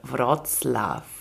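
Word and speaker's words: Wrocław: Und zwar nach Breslau bzw. Wroclaw. Richtig ausgesprochen? – Ja, also wenn wir jetzt keinen Protest von den äh, polnischkundigen äh, Hörerinnen haben, dann war es richtig.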